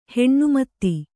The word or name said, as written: ಹನುಮತ್ತು